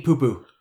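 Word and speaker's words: Pu'pu: Because that's all they do is mispronounce to P-U apostrophe P-U. How do you pronounce that? Papa? – I'm looking it up. – Pew, pew. – Pronounced poo-poo. I'm not joking. It's Nanny Poo-poo.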